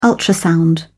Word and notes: ultrasound: British English

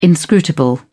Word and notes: inscrutable: British English

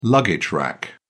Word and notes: luggage rack: British English

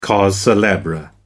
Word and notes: cause celebre: American English

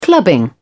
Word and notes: clubbing: British English

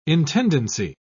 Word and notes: intendancy: British English

